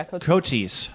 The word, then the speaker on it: Cochise: Yeah, Cochise.